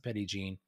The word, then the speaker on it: Petit Jean: So, I mean, the most popular one is what locals call Petit Jean. It looks like Petit Jean. If you look it up on the map, they pronounce it Petit Jean.